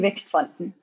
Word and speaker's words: Vækstfonden: I can just help and say Kinnevik and Vækstfonden.